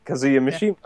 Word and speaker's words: Kazuya Mishima: You said it right, Kazuya Mishima.